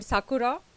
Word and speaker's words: Sakura: So we live in this independent house in a city called Sakura. It's not the cherry blossom Sakura, but it's pronounced the same way.